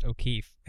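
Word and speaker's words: O'Keeffe: It's pronounced O'Keefe.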